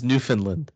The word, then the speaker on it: Newfoundland: And I believe it's pronounced Newfoundland.